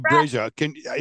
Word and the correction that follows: Abrego: is that how we pronounce his last name? – Abrego, Abrego, yeah.